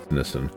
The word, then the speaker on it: Nissin: I believe it's Nissin.